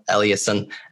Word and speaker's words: Eliassen: But it's just at Raphael Eliasson. And the last name is E-L-I-A-S-S-E-N.